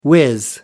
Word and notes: whiz: British English

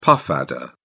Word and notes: puff adder: British English

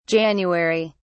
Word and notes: January: British English

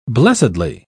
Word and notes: blessedly: British English

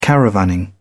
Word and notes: caravanning: British English

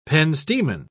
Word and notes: penstemon: British English

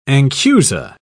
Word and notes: anchusa: British English

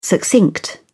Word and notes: succinct: British English

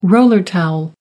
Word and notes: roller towel: American English

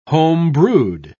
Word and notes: home-brewed: British English

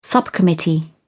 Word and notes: subcommittee: British English

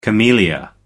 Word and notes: camellia: American English

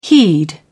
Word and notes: heed: British English

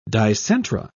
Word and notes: dicentra: British English

